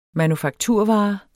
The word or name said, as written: manufakturvare